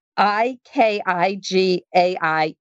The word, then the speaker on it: Ikigai: but it's it's I K I G A I Ikigai, and what he found was those individuals with the with a sense of purpose live longer.